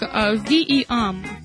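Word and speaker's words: ВИАМ: Всероссийский научно-исследовательский институт авиационных материалов, который сокращенно, я буду сокращенно в следующий раз говорить, произносится как VEAM. Вот так вот.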